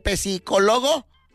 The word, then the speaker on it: psicólogo: Bueno, se encuentra el psicólogo. Dice, no se encuentra. Dice un detalle, la P no se pronuncia, pero quiere dejar un recado.